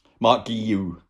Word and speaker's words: Guiu: Um, presumably, you're talking the, the, the one you'll be talking about will be will be um, Mark Gue. Marc Guiu.